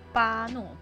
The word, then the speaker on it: Bruno: 麦特戴蒙的短片里，他的发音是巴诺，不是 O，是啊。